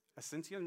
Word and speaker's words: Asunción: Now, Catira is a shanty town of 40,000 people on the outskirts of the capital, Asunción. I probably didn't say that right, I'm so sorry. Asunción, not bad? Thank you.